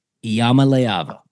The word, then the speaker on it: Iamaleava: Iamaleava. – There you go. That is how you pronounce Polynesian Peyton's last name, Nico Iamaleava.